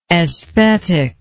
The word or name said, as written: esthetic